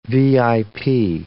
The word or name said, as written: VIP